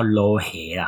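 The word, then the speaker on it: 撈起: 那我记得他们的发音叫捞黑啦。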